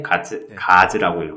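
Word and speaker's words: Gods: 그 GODS라고 하는데 가즈라고 읽어서 뭐 이런 식으로 부르거든요.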